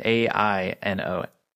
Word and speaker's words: Aino: It's called Aino. I think I'm saying that right. A I N O. Aino.